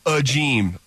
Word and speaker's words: Agim: His last name is pronounced Ageem, although it's spelled A-G-I-M.